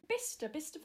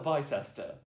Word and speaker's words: Bicester: But it's spelt Bicester. – Yes, but that's not how a human pronounces it.